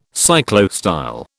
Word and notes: cyclostyle: British English

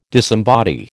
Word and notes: disembody: American English

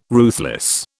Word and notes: ruthless: British English

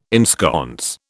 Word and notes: ensconce: British English

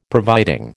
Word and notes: providing: American English

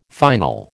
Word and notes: final: American English

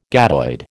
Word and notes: gadoid: American English

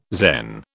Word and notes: Zen: British English

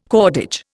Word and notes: cordage: Indian

